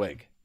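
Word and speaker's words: Mornhinweg: Here's what offensive coordinator Marty Morenwig. I know how to say it, but then when you look at the spelling, it actually will trip you up. – It's that H that trips you up.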